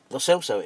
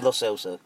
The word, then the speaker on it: Lo Celso: Lo Celso it is. I had an ongoing debate, and I saw Twitter last week. He's pronounced it. – Yeah. – Lo Celso.